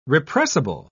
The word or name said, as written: repressible